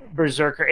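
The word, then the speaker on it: BRZRKR: And now the actor will produce and star in a live-action Berserker. It's so weird how it's spelled. It's B-R-Z-R-K-R. So it's like, I know it's Berserker, but it's hard to, like, read it that way.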